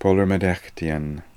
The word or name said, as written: Polormedekhtian